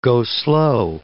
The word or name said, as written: go-slow